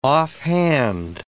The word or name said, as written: offhand